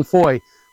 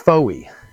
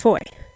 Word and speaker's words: Fowey: And then you march into downtown Foy, which, if you were going to pronounce it phonetically, is Fowey. – F-O-W-E-Y. – That's right. – But we learned it's Foy. – Rhymes with joy.